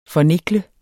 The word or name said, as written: fornikle